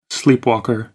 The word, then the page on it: sleepwalker: American English